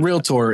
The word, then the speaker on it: realtor: Realtor is done. – Yeah, I think he says realtor this time. Raylan's learned how to pronounce it correctly.